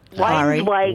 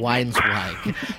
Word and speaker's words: Weinzweig: Well, the gentleman in question, I never pronounce his name correctly. – Weinzweig. – Weinzweig.